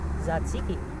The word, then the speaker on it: Tzatziki: If you're Greek, you combine the T and the S. If you're American, you just drop the T. – Okay. So it's a little bit like, and I'm not going to not pronounce this correctly, Tzatziki. – Yes. – Yes, because it has a T-S, right? – Remember Governor Tsongas?